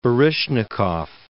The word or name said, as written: Baryshnikov